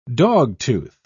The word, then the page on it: dogtooth: British English